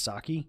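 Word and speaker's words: Psaki: The new press secretary, I believe her last name is pronounced Saki, was asked about this, and here was her response.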